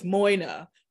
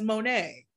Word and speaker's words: Moyna: Because when I started this, I started with Life with Moyna, pronouncing my name that way, because phonetically, that's what it says. But my mom, being the interesting trini that she is, raised me to pronounce M-O-Y-N-A as Monet. That's not what that says, but it is what it is.